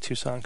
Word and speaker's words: Tucson: And I did pronounce Tucson correctly that time.